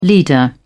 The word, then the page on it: leader: British English